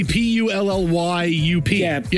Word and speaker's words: Puyallup: Well, yeah, that's the Puyallup. – Yeah. – You see it all the time. – Puyallup.